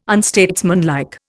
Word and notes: unstatesmanlike: Indian

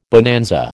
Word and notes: bonanza: American English

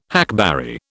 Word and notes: hackberry: British English

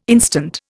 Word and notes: instant: Indian

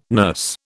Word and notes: nurse: British English

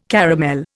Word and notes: caramel: Indian

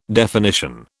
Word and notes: definition: British English

